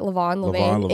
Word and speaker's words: Levain: Levain, in New York? – Yeah.